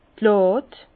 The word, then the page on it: d' Làd: Alsatian; Bas-Rhin; Herrlisheim